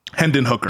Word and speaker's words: Hendon Hooker: Hendon Hooker.